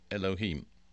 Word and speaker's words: Elohim: We'd probably spell that in English E-L-O-H-I-M, Elohim.